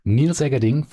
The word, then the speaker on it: Niels Eggerding: We have with us Niels Egerding from Frederick Constant.